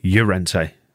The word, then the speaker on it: Llorente: Sorry, Llorente, to give the proper pronunciation.